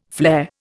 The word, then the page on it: flair: Indian